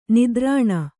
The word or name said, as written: ನಿತ್ತರಣೆ